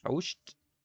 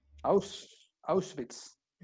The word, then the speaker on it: Auschwitz: Auschwitz. – Bueno, esa cosa. – Auschwitz.